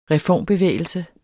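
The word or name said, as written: reformbevægelse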